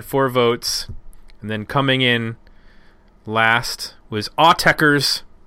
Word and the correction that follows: Autechre: I think it's Autechre. – Repita. – I think Autechre is how you pronounce the band name.